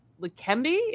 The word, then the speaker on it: Leqembi: And the FDA granted full approval of Leqembi. Is that how you pronounce it? – Mm-hmm. Yes.